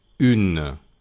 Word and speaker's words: une: Tenemos el masculino que se pronuncia un y el femenino que se pronuncia une. Repito, un, une. Son bastante diferentes.